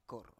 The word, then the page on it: kor: Biak